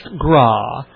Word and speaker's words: Graae: And the main thing is, if you know who he is, you know how to pronounce his name, G-R-A-A-E, which is pronounced Graw.